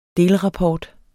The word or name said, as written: delrapport